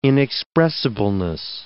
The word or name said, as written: inexpressibleness